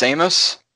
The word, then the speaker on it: Samus: It's S-E-A-M-U-S. It's Samus. – Samus.